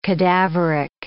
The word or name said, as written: cadaveric